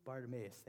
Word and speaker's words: Bartimaeus: It's Jesus healing blind Bartimaeus. Is that how you say it? How do you say it? Bartimaeus, thank you.